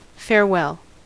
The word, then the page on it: farewell: American English